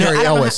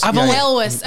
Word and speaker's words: Elwes: Because that's what I put Carrie Elwes. – Elwes.